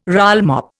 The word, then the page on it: rollmop: Indian